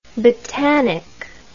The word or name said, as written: botanic